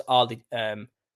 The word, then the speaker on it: Arlecchino: So Harlequin, the name Harlequin, it only comes from, it's pronounced Arlecchino. So Harlequin, in Italian they call it Arlecchino.